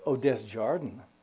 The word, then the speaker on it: Desjardins: Am I pronouncing the last name right? How'd you guys pronounce it? Desjardins. Sounds kind of gringo, bro.